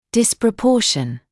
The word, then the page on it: disproportion: ˌdɪsprə’pɔːʃn; ˌдиспрэ’поːшн